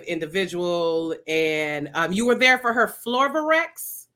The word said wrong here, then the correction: Flow Vortex: Oh, Flow Vortex. It's the Flow, flow Vortex. vortex.